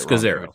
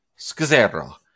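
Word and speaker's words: Scazzero: I think I say it Scazzaro. – Wrong. Scazzaro.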